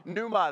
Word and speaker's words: pneuma: Pneuma, that's no idea how you get that, right? Pneuma, that's how it's pronounced.